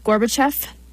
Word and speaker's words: Gorbachev: I think is how you pronounce it, Gorbachev.